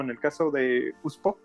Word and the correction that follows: Usopp: Así Usopp.